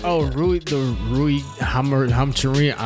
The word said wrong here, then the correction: Hachimura: RUI Hachimura.